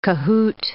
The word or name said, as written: cahoot